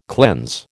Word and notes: cleanse: American English

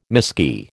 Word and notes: miskey: American English

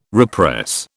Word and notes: repress: British English

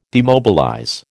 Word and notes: demobilize: American English

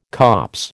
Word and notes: copse: American English

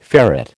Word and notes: ferret: American English

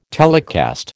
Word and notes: telecast: American English

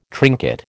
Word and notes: trinket: American English

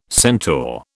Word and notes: centaur: British English

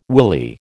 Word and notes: willy: American English

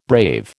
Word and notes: brave: American English

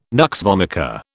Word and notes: nux vomica: British English

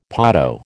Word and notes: potto: American English

